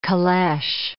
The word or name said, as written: caleche